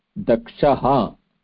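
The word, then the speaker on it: Dakshah: No, Daksha is Dakshaha. Is the Visarga, like Ramaha, Krishnaha, Keshavaha. So that is a Pullinga.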